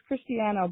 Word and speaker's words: Christiana: It's Christiana, by the way.